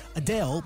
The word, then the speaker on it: Adele: So in a new Q&A on YouTube, Adele praised a fan who properly pronounced her name. So it turns out it's not Adele, as most of us have been saying, but Adele. Adele. Anyway, this is the official pronunciation we've been sent from her music label that we play here at Kiss FM.